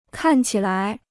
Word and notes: 看起来: kàn qǐ lai

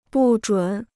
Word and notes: 不准: bù zhǔn

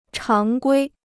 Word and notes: 常规: cháng guī

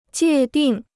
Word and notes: 界定: jiè dìng

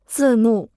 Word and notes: 字幕: zì mù

